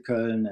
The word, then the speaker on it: Köln: We were discussing that Köln and Cologne is the same, and how to pronounce it. Köln. The people from Köln they say more Köller. I'm from Köller, so that's a dialect.